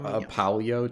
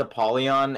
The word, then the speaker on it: Apollyon: Did you look up Apollyo too, or is it Apollyon? – Yeah, it's Apollyon, and basically it sounds like a Pokemon.